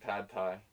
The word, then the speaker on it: pad Thai: And, like, how pot Thai, which is, we pronounce it pad Thai, is, like, completely made different in America.